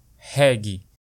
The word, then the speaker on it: reggae: Tudo isso graças ao ritmo que, nos anos 1970, conquistou o coração dos maranhenses, o reggae, ou como dizemos na pronúncia brasileirada, reggae.